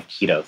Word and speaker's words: Kito: I know, it's spelled K-I-T-O, but it certainly sounds like the other one, doesn't it? – Yeah, like the diet.